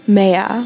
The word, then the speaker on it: Mayer: Mayer. – Okay, so you've got that English pronunciation. It's not like Mayer.